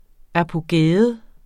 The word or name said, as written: apogæum